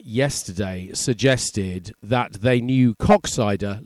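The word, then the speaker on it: Koksijde: And for any 14-year-old boys listening, yes, Coxsider is pronounced Coxsider.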